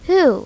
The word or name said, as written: who